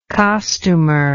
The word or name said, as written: costumer